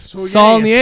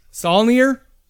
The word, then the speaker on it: Saulnier: Saulnier. It's French. Saulnier.